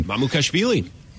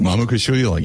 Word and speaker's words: Mamukashvili: Mamukashvili, former Mamukashvili.